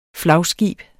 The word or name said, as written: flagskib